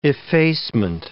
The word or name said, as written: effacement